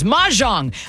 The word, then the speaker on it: mahjong: Mahjong.